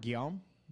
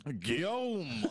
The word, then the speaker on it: Guillaume: Oh, Robert Guillaume? – Sure. – Yeah. – Guillaume.